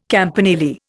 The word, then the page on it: campanile: Indian